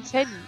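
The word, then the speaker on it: Shallie: Also Atelier Chalier, wird das so ausgesprochen? – Shelly. – Shelly? Mhm. Okay.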